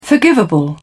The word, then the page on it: forgivable: British English